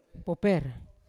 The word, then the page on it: poper: Yawa